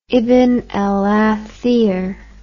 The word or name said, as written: ibn al athir